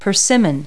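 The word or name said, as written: persimmon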